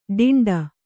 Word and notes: ಡಿಂಡ: ḍiṇḍa

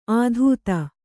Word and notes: ಅತೀತ: atīta

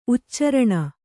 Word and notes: ಉಚ್ಚಾರಣೆ: uccāraṇe

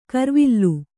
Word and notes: ಕರ್ವಿಲ್ಲು: karvilllu

